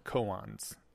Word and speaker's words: koans: And our first one is two cult koans. Is that how you pronounce it? Does anyone know? – Yeah.